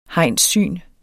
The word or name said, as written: hegnssyn